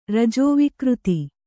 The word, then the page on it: ರಜೋವಿಕೃತಿ: rajō vikřti